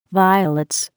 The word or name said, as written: violets